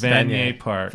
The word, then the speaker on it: Vanier: Vanier Park. – Vanier Park, and that's where we are.